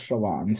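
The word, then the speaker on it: Chelan: I mean, like, it's spelled C H E L A M, but it actually is pronounced Shalon.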